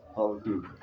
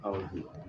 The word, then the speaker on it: Habakkuk: Habakkuk. – Habakkuk? – Habakkuk.